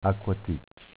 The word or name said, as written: Acquaticc